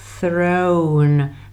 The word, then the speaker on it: throne: A vzít trůn je úplně stejná výslovnost. Take the throne.